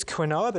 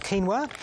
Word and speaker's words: quinoa: It reads quinoa, but actually it's pronounced quinoa.